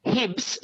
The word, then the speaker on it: Hibbs: His name is Hibbs? Hibbs? – Yes. I'll just spell the middle name. Kenny, K-E-N-N-E-Y. – Yeah. – Hibbs, surname, H for Harry, I-B-B-S.